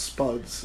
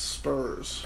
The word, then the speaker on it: Spurs: Yeah, it's not Spuds. It's Spurs.